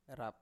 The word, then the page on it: rap: Biak